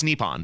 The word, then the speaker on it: Nippon: How do you say it? Nippon? – I believe it's Nippon.